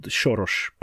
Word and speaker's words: Soros: Initially, I was thinking it was Angela Merkel smuggling people in the back of her limousine, but no, should have known it's Soros. – No, even Angela Merkel is in the pocket of George Soros, should be called Soros, by the way, because that's how we pronounce it in Hungarian.